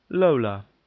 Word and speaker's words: Lola: Lola. – How'd you spell that? – L-O-L-A, Lola.